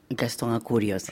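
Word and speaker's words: Gastón Acurio: c'est Gaston Ancurio. Je prononce correctement ? – Gaston Ancurio.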